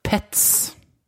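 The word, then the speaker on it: PEZ: det her står det på, på tysk, så uttales det Pets.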